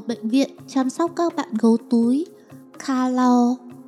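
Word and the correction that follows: koala: Đọc là qua la nhé đi Cậu mà đọc thế là ra thành nước ngọt Coca-Cola mất rồi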